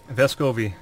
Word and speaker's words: Vescovi: Vescovi.